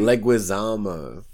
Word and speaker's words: Leguizamo: Can you pronounce his name? – Leguizamo. – There you go.